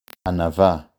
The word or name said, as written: ענווה